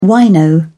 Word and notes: wino: British English

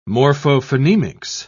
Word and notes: morphophonemics: British English

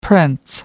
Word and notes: prince: American English

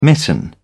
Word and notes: mitten: British English

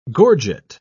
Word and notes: gorget: British English

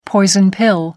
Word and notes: poison pill: American English

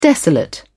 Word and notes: desolate: British English

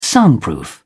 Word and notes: soundproof: British English

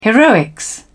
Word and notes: heroics: British English

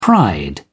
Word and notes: pride: British English

